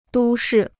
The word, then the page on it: 都市: dū shì